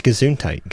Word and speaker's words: Gesundheit: It is Gesundheit. Gesundheit means bless Gesundheit. You in German. And that is spelled. – Yeah. – Yeah, I'm curious. – G-E-S-U-N-D. – Gesund. – H-E-I-T.